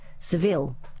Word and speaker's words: Seville: Apparently, it's pronounced Seville after the city in Spain.